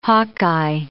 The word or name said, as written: Hawkeye